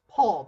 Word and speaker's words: Pool: And we get this through the eyes of a character named, I believe it's pronounced Paul, though it's spelled Pool on the Big Finish website,